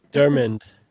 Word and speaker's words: McDiarmid: McDiarmid, yeah.